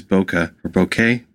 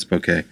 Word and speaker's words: Bokeh: Last time I tried to use Bokeh, I keep saying that wrong. You don't need to email me that I'm saying it wrong. I know it's Bokeh. It's Bokeh.